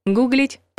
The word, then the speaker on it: гуглить: Слово разговорное, но раз произошло от Google, индексить, согласитесь, сложнее, то и в других формах сохраняет ударение на первом слоге корня. Гуглить, погуглить, нагуглить.